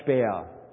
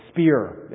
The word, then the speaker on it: Speer: But this parade ground was the work of a young architect by the name of Albert Speer, or Speer, as you guys might pronounce it.